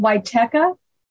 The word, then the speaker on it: Wietecha: It's pronounced Wichetka, like the YMCA, Teka.